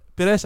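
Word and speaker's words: Pérez: Perez eindigde als zevende.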